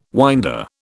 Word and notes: winder: British English

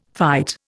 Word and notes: fight: Indian

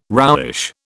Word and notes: roughish: British English

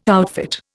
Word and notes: outfit: Indian

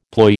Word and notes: ploy: American English

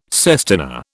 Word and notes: sestina: British English

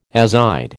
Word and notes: azide: American English

